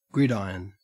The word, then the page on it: gridiron: Australian English